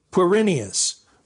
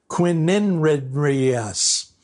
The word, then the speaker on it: Quirinius: Mr. Gamage stumbled over the name Quirinius, which he'd always gotten right in practice, this time adding a syllable and pronouncing it Quininridrius.